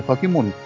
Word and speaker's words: 防人: ああこれでね、防人と書いて先森って。そうそうそうそう。う俺も読まれへん。防ぐ人って書いて、まあ、先森と読むんだけども、